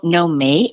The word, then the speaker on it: Nomi: Nomi," and "Know Me" is spelled N O M I.